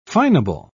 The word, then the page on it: fineable: British English